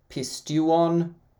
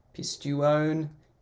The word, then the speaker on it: pisteuon: Um, you can see there, pisteuon, pisteuon is how you say that.